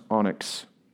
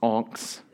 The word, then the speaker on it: onyx: It's pronounced onyx. Not onx.